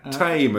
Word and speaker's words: Thame: Tame is a place name I always find amusing. – Mm. That's right. It's spelt Thame, right? – It's spelt Thame, but it's...